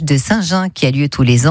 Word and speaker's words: Saint-Jean: Ça s'écrit Saint-Jean, mais ça se prononce Saint-Jean. – Et oui, à la Provençale, bien sûr.